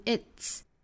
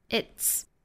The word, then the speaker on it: it's: 那我们来看第一个词就是 it, 后面加的这个 s, 我们注意它应该读 it's, it's. 那尾部呢应该是一个 ts 的发音很像我们汉语里面刺的那个声母 it's, it's.